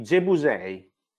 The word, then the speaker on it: Gebusei: Come si chiamano i Gebusatisi? – I Gebusei. – Gebusei. Ha detto bene, ok, perché è molto importante.